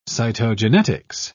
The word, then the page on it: cytogenetics: British English